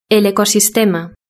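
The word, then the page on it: el ecosistema: Spanish (Spain)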